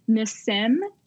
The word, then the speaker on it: Nassim: Nassim. And I know that sounds like I'm saying it wrong, but that's how he says it.